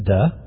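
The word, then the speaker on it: duh (δέ): But the word that's translated even is a little word that's pronounced duh. It's delta epsilon.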